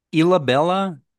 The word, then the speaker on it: Ilhabela: Ilhabela, Sao Paulo State, Brazil.